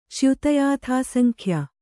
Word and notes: ಚ್ಯುತಯಾಥಾಸಂಖ್ಯ: cyuta yāthā saŋkhya

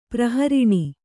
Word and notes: ಪ್ರಹರಿಣಿ: prahariṇi

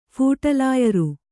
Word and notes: ಫುಟ್‍ಲಾಯರಿ: phuṭlāyari

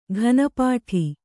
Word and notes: ಘನಪಾಠಿ: ghana pāṭhi

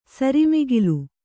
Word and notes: ಸರಿಮಿಗಿಲ್: kannada

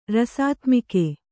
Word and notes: ರಸಾತ್ಮಕ: rasātmaka

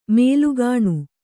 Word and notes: ಮೂಲಗೇಣಿ: mūla gēṇi

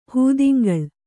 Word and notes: ಹೂದಿಂಗಳು: hūdingalu

